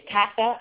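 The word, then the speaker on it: Itasca: It's Tassa, I believe that's how you say it, Illinois.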